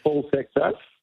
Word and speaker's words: Paul Seixas: It is spelled S-E-I-X-A-S. We all had to go directly to Paul to check the pronunciation of his name because we didn't want to get that one. – No. – It is Paul Seixas.